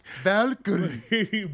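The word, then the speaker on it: Walküre: Her name's not Valkyrie. It's W-A-L-K-E-R-E. – Yeah, Valkyrie. – Pronounced Valkyrie. – Valkyrie.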